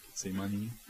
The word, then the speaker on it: Gethsemane: Yeah, Gethsemane.